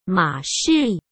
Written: Ma Shi

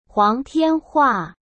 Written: Huang Tianhua

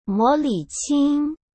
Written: Mo Liqing

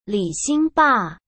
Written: Li Xingba

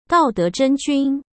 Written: daodezhenjun

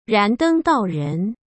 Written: Randeng Daoren

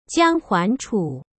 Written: Jiang Huanchu

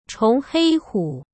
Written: chongheihu

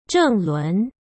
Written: zhenglun

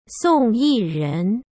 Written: Song Yiren

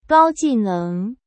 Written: Gao Jineng

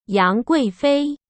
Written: yangguifei

